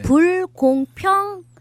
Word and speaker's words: 불공평: 근데 세상을 이렇게 돌아가는 거 보니까 정말 불공평하게 불경퐁 발음이 잘 안 나요. – 네. – 불공평 많더라고요.